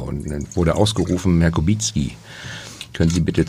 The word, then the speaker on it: Kubicki: Polnisch würde ich auch Kubicki ausgesprochen. – Ja, aber... Ja. Also wenn Sie in Polen sind und Ihren staatsmännischen... – Dann werde ich Kubicki angesprochen.